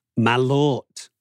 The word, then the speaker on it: Malört: Malort.